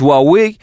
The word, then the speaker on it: Huawei: Это смартфоны, которые Huawei у нас их называют, вообще правильно говорить Huawei, отключат от платформы Android.